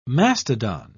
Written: mastodon

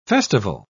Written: festival

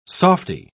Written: softy

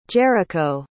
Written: jericho

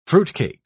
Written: fruitcake

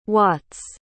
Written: watts

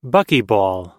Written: buckyball

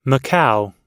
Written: macao

